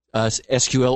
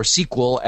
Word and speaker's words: SQL: is pushing SQL or SQL as it is often, as that acronym is often pronounced.